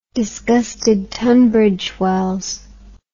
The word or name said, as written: disgusted tunbridge wells